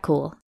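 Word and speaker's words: Kuhl: I'm Kat Cool,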